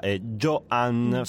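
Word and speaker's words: JoAnn: A suonarlo saranno la Stuttgarter Philharmoniker diretta da Joanne Faletta. Lo dico spezzato perché effettivamente il suo nome si scrive con la A in mezzo maiuscola, che è una cosa che non ho mai visto in vita mia.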